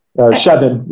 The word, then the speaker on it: Shevin: Kevin, like Shevin, like Kevin.